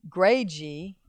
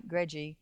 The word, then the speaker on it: greggii: That salvia plant that I bought at Petals from the Past that has the name is Gregi, I guess that's how you pronounce it, G-R-E-G-G-I-I, Gregi, raspberry was the variety.